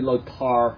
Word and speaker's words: Lothar: But Lothar. It's actually Lothar, but we pronounce the T-H, they don't. It becomes hard.